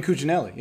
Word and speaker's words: Cuccinelli: Oh, Ken Cuccinelli, Ken, is that how you say it? Cuccinelli. Yeah.